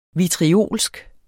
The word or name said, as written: vitriolsk